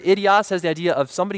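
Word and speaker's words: idios (ἴδιος): If you look in the Greek, if you transliterate that word own, you take the Greek word and you pronounce it like in English, it's the word idios. Sounds a lot like idiot, doesn't it?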